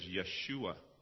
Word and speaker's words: Yeshua: The Aramaic pronunciation for the word Yehoshua is Yeshua, which is the name Jesus.